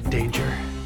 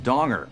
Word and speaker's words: Danger: Jack Danger. – It's actually pronounced Donger. It's derived from a Dutch word meaning prudence in financial matters.